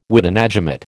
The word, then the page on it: witenagemot: American English